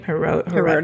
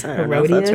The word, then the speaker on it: Herodias: So, how did you say her name? – Herodias. – Herodias. That's right.